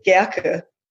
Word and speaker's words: Gerke: It's Swiss German, so from Basel, so we pronounce it Gerke. But you were close enough.